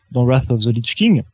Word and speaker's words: Wrath of the Lich King: Wrath of the Lich King. T'as vu comment je le prononce? – Waouh. C'est beaucoup mieux, hein.